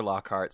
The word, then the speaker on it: Lockhart: It's B-E-Y-O-N-C-E. It sounds different than it's spelled. It's at Chester Lockhart, C-H-E-S-T-E-R-L-O-C-K-H-A-R-T. It's like Lockfart, but with an H.